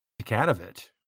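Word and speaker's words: Jokanović: just quickly, how do you say Djokanovic? – Djokanovic. – That'll do.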